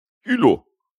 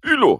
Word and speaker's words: Hulot: Das ist wahrscheinlich auch mein größter Fehler, dass ich irgendwelche Sachen mit, also so wie Psycho oder Hulot, dass ich die dann mitspreche, so die normalerweise nicht mitgesprochen werden.